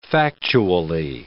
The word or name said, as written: factually